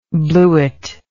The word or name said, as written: bluette